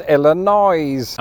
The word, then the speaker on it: Illinois: We don't pronounce the S here in Illinois. but some of you chuckleheads do. But anyways, today's big question: why do some Americans pronounce this state as Illinois?